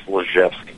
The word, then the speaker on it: Lizewskie: Sure, it's Lizewskie.